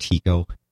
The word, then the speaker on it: Tycho: Do your name's actually properly pronounced Tycho? – Really? No. – Yeah. Tycho Brahe.